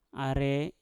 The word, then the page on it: are: mpur